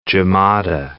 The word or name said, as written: Jumada